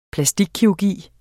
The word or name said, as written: plastikkirurgi